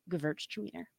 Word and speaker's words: Gewürztraminer: And a little pneumatic helper for you, if you're ever having trouble pronouncing this one, think girls are meaner. Gewürztraminer.